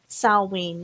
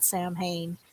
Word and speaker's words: Samhain: Salwayne, not Samhain.